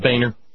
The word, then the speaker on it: Boehner: But I spelled out Boehner, Andy.